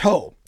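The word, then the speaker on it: ho (ὁ): If you looked at it in Greek, it's a zero with a little breath sound over the top of it. Looks like a comma, right, over the top of it. And it's pronounced ho almost everywhere.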